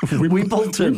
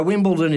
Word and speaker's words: Wimbledon: No, Wimbledon is the correct pronunciation. There's no T in Wimbledon.